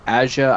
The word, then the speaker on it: Aja: Okay, but yeah, the Matt Fraction, David Aja, I think is how you pronounce his name, AJA.